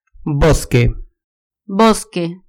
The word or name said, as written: bosque